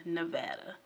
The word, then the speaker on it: Nevada: It was located on 63 acres of land along the Las Vegas Strip in Nevada. And yes, it's pronounced Nevada, not Nevada. Someone from Nevada told me that.